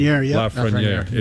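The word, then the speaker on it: Lafrenière: You've got that Alex Lafreniere kid. I think I'm pronouncing that correctly. – Lafreniere.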